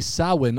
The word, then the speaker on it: Samhain: Now, we got to pause here because this word does not look like it's pronounced Samhain, but it is. – At all. – It's spelled Samhain, and it's an old Gaelic word, but you pronounce it Samhain or Samhain. I'm doing my best with that, but it's not Samhain.